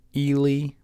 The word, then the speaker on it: Ely: And it's pronounced Ely, Minnesota. E-L-Y. – Spelled Eli. – Yeah. – But it's Ely.